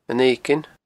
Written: Eiginn

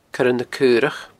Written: coirenacaorach

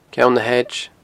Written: Ceann na h-Edge

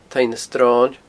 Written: taigh na sroine

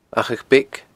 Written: Achadh Beag

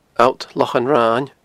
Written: Allt Lochan Rain

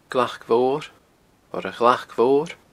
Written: glacmhor

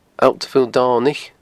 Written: Allt Mhaol Domhnaich

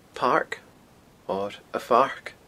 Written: pairc

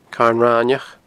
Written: Carn Raineach

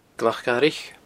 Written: glacdharaich

